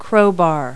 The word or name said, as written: crowbar